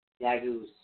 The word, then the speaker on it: Dagaz: It's actually spelled D-A-G-A-Z. But you pronounce it D-A-H-G-A-U-Z-E. – D-A-G-U-E. – G-U-G-A-U-Z-E. Dagus.